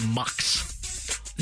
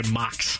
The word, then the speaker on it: Mocs: They're the Mucks. – That is quite dicey. – The Mucks. – What is that? How do you spell it? – M-O-C-S. – What is it? – Short for moccasin. – Got you. – Yes, but they just go by Mucks.